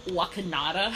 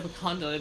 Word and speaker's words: Wakanda: and and hearing Bruce trying to say Wakanda as Wakanada, I'm like, Bruce, no. Say it right. I was like, say it right. And then when he said Wakanda, and all of our ears, all us comic book geeks were like, T'Challa, Black Panther, wait, what?